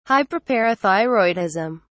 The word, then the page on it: hyperparathyroidism: British English